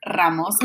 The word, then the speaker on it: Ramos: As she mentioned, my name is Jennifer Ramos. Actually, pronounced the R, sis.